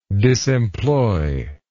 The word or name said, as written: disemployed